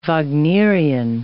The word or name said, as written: Wagnerian